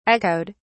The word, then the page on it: echoed: British English